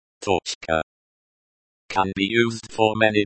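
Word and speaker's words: Toczka (точка): Pronunciation. Toczka. Toczka. Stress on the first syllable.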